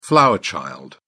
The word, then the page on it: flower child: British English